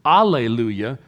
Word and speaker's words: alleluia: If you leave off the H and you pronounce it alleluia, that's simply the Greek version of the Hebrew word.